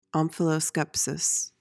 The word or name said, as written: omphaloskepsis